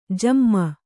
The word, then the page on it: ಝೊಮ್ಮು: Kannada